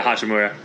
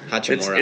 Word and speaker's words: Hachimura: Like, they had some decent players like Thomas Bryant and Hachirama. Like, they had some pretty good players. I just butchered his name. I don't know. Yeah, Hachimura. – Hachimura.